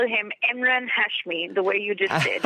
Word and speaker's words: Emraan Hashmi: It's Emraan Hashmi.